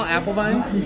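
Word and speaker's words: Apfelwein: Can we get two small apple vines? – Yeah. – Thank you. – Yeah. – How do you say this? Apple wine or apple wine? – Apple vine. – Apparently it's called apple vine.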